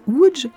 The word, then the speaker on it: Łódź: La ville de Łódź, ça se lit Lodz, ça se prononce Łódź,